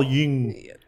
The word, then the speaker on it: Jung: I call him Carl Jung.